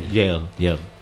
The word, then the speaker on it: Yale: Yale.